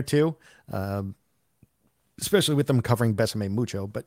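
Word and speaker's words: Bésame Mucho: Besame Mucho.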